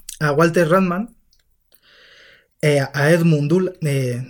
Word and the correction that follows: Ruttmann: pues mira, Walter Ruttmann fue pintor y fue un cineasta experimental que eh, hacía cortos muy abstractos.